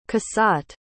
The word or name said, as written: cassatt